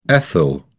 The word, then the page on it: ethyl: British English